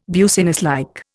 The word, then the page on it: businesslike: Indian